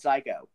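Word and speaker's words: Saiko: It's called Psycho. – Psycho. – But it's spelled with an S. It's like S-A-I-K-O, I think is how they spell it.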